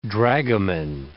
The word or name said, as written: dragoman